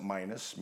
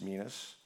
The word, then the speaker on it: minas: Some pronounce it minus, minas, same thing.